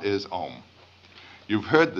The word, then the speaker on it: Om: Now, the sound O in Sanskrit is analyzed into two sounds, A and U. So this can be written either O-M or A-U-M, and you'll see it both ways. When one pronounces OM, the sound starts in the back of the mouth, ah, and then it goes, fills the mouth cavity, oo, and then it closes at the lips, m.